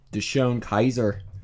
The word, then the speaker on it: DeShone Kizer: DeShone Kizer. They should call him the Kizer. You know? Like the German Emperor.